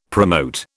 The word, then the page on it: promote: British English